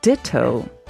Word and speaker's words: Ditto: He was the former principal conductor of the Ditto Orchestra. Do I say that right? – right. Ditto or D I T T O?